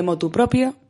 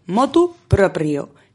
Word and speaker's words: motu proprio: Si tú, la mayoría de la gente sigue diciendo de motu propio, tú no les digas nada, pero cuando tú lo pronuncies, queridos oyentes, cuando lo pronunciéis, hacedme el favor, pronunciadlo bien, motu proprio, sin proposición.